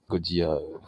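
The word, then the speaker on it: Gojira: Godia. – Si, Godia. Oui, Godia. Oui, c'est la prononciation.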